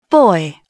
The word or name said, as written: boy